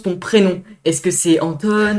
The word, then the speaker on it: Antton: C'est Antione. Ça se oh. prononce Antione. Ça s'écrit euh, bizarrement, mais ça se prononce Antione.